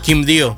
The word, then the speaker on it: Quibdó: Quibdó.